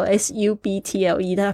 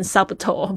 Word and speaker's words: subtle: more subtle，这个 subtle s u b t l e，大家不要念成 subtle，不要，千万不要哦。这个 b 是不发音的哦，subtle。